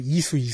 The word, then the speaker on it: 이수: 인수라고 발음했는데 이수 이수.